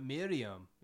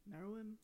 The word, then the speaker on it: Meruem: Takes off the makeup, but Miriam is. – I think from Hunter Hunter. – Meruem.